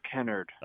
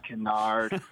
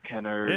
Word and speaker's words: Kennard: And my last name is pronounced Kennard, even though it's spelled the same. So I see. – I've been dealing that my whole life. – I bet. – They call me Kennard, and I'm like, no, it's Kennard.